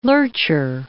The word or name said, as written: lurcher